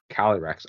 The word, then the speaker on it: Calyrex: Calyrex? – Calyrex.